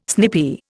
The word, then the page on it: snippy: Indian